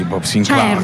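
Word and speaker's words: Bob Sinclar: Bob Sinclair, è perché si pronuncia così, Bob Sinclair.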